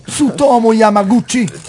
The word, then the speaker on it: Tsutomu Yamaguchi: Che Tsutomu Yamaguchi, penso di averlo detto giusto. – Ma certo, Tsutomu Yamaguchi.